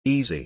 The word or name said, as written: EZ